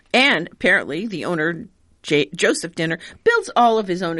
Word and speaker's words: Dehner: Is that how you pronounce this? D-E-H-N-E-R? – I tried Dehner. – Dehner. Okay. There you go.